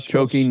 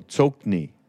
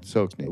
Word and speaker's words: Tsokni: Tsoki Rinpoche is how it's supposed to be pronounced, I think. – Tsoki, you know? – Not Tsoki Nima, no, Tsokni. – Oh, Tsokni. Oh, yeah. – Yeah, Tsokni.